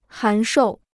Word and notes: 函授: hán shòu

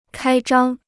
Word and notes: 开张: kāi zhāng

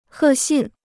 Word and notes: 贺信: hè xìn

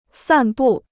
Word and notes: 散布: sàn bù

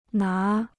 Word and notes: 拿: ná

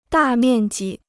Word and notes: 大面积: dà miàn jī

